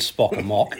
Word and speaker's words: Spock Amok: It is indeed Spock Amok, yes. And my basis for that is entirely because it rhymes.